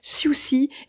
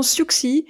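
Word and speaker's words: Siouxsie: Son nom de baptême, c'est Suzanne Ballion, voilà pourquoi on prononcera plutôt à l'anglaise Susie et non Suxi, car le jeu de mots est un clin d'œil au surnom de Suzanne, Suzy.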